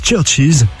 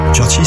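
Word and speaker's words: CHVRCHES: Écoutez, c'est Roses Churches sur RTL2. Churches, ça s'écrit avec des V à la place des U.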